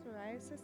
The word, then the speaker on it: psoriasis: I can't pronounce that word. Can you help me? Psoriasis. There you go.